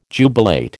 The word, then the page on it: jubilate: American English